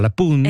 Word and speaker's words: La Punt: Et moi, j'ai appris comme parole, je ne savais pas, qu'on dit, on dit pas le pont, on dit la, donc c'est féminin, Pun, alors qu'il y a un T à la fin, mais on ne le prononce pas, la Pun.